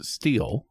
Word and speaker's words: Stihl: According to them, it is pronounced steel.